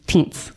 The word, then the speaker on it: Thuns: It's spelled kind of weird because I'm sure it's like a South African name. It's spelled T-H-U-N-S. But looking it up, his name's Teens.